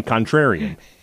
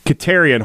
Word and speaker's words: Katerian: Am I saying it right? I feel like I'm saying contrarian. Katerian. There you go.